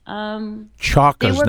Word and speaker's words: chakras: Chakras. Now, are we saying that right? Chakras. – Chakras. Chakras. – Okay. – Yes. – Because I'm almost close to saying chocolate, and it's not chocolate.